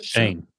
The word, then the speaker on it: Schoen: Is it Schoen? Wait, what is it? – Shane. – How's it not Shane? Like Shane Bacon. Just Shane. – It makes no sense. Spell it Shane then.